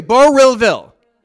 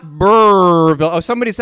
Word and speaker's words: Burrillville: Is it like Burrville or is it Burrillville? How many syllables? It's three. Burrillville. It's not Burrville.